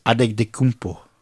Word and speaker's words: Antetokounmpo: Did you actually say it correctly just now? – Giannis Antetokounmpo.